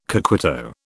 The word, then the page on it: coquito: British English